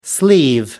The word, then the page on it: sleeve: American English